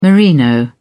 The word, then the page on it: merino: British English